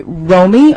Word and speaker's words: Romi: Romy, R-O-M-I, Slowiak, S-L-O-W-I-A-K.